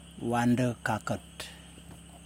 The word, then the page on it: wandukakut: Yokoim